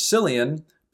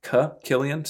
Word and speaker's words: Cillian: And then the rest of the movie, the other big actor that they brought in for this one is Cillian Murphy, who I always used to pronounce as Cillian, but then I learned that the Irish or Scottish pronunciation is actually Cillian.